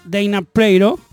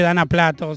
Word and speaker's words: Dana Plato: Eh, ella se llama Dana Pleiro. Se pronuncia así o se escribe Dana Plato, si lo leemos en español.